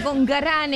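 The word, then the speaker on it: Concarán: con Carán, es el lugar con Carán, así con acento en la última A, San Luis, provincia de San Luis.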